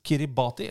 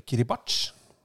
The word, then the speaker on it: Kiribati: Mens Kiribati, eller Kiribach, som det vel strengt tatt eh, skal uttales, ja eh, dessverre måtte melde avbud.